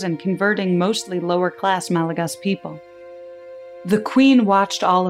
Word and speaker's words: Malagasy: A brief side note the native language on Madagascar is spelled Malagasy phonetically, but pronounced, to the best of my ability and understanding, Malagas.